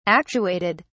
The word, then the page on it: actuated: British English